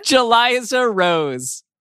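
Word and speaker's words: Jeliza-Rose: Jaliza Rose.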